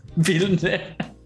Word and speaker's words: Villeneuve: ¿Villeneuve? ¿Lo dije bien? – Villeneuve.